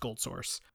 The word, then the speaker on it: GoldSrc: spelled GOLDSRC, but pronounced Gold Source.